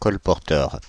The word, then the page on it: vendor: American English; [vɛn.də(r)]